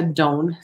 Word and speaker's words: Doane: Ramón Down, y él pronuncia su nombre Ramón, aunque se escribe Raymond, y el apellido lo pronuncia Down, se escribe D-O-A-N-E es eh, nuestro candidato para el Senado.